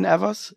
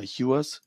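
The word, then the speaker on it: Ewers: Nun, ich glaube, es wird Quinn Evers, ja, oder Hewers, ich weiß nicht, gibt Quinn ja immer zwei. – Ja, die sind mit der Aussprache auch alle nicht einig. – Ja, gibt ja immer, das ist ja so Evan und Hewen ist ja der Vorname dazu. Gibt ja auch beide Aussprachen, je nachdem, wie man es gerade machen will.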